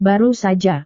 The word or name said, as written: baru saja